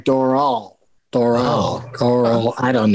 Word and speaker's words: Doral: The improv in someone recently told me, I always call it Doral, but somebody recently told me that it rhymes with coral. So it's more like Doral.